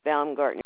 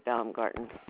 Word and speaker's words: Baumgarten: The last name is Baumgarten. It's B as in boy, A-U-M-G-A-R-T-E-N, Betty Baumgarten.